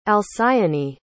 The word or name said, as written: alcyone